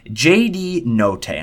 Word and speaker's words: Notae: J.D. Notae, and I believe I pronounced that right. N-O-T-A-E.